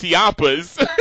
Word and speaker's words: Ciampa: Okay, so Champ. Wait, where was the H at? – There is no H.